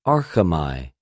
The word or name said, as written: ἀρχομαι